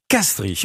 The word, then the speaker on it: Castries: Castries, ok, ça s'écrit IES à la fin, ça se prononce Castries.